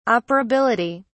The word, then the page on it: operability: British English